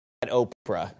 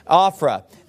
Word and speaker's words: Ophrah: And don't pronounce that Oprah, all right? Ophrah.